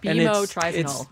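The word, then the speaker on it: bemotrizinol: It's bimotrizenol. – Bimotrizenol. – You emphasize the mo.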